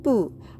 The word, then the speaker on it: 不: そして、えー、再びですね、このプーというのが来てますね。不可能の不。これは否定を表す、えー、文字になります。プー発音記号は BU の第 4 声上から下に急降下する音